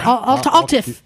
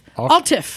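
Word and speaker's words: araf: How do you say slow down in Welsh? – Yeah, Altif. Altif.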